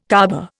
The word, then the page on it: Caaba: Indian